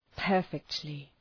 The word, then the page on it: perfectly: ‘pɜ:rfıktlı